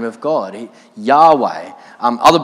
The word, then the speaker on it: Yahweh: It's YHWH. And we think it's pronounced Yahweh.